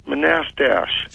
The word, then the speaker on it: Manastash: Do you know where Manistash? – Yeah, it's pronounced Manastash.